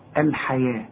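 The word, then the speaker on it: الحيوة: اشتروا الحياة، الحياة مكتوبة بالواو ولكن تنطق بالالف، ورسم لك الف فوق الواو بيقول لك انطقها الف كامله الحياه، لكن اذا كتبتها تكتبها بالواو.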